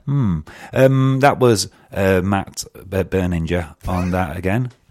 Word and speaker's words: Berninger: Berninger. – Yeah. It's a hard G.